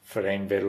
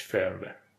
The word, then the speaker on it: فرمور: نمیدونم چرا تلفظ های خیلی عجیبی در ایران رایج شده از فرمور مثل هاردور. فریمور و اینا نیستش، فرمور. امیدوارم حتی درست بگم.